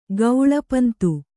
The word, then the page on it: ಗೌಳಿಪಂತು: Kannada